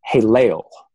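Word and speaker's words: Hallel (הילל): the Hebrew word in Isaiah 14:12 is pronounced Hallel.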